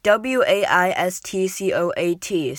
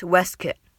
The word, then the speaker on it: waistcoat: The last, the next word is waistcoat, so it's actually spelled like W A I S T C O A T, so you'd expect it to be pronounced like waistcoat but it's actually nothing like that. It's spelled, it's pronounced Westkit.